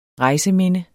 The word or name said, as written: REJSEMINDE